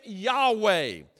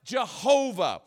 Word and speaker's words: Yahweh: name Yahweh, or the, it was sometimes pronounced Jehovah. And this is the greatest name probably of given to God in the Old Testament.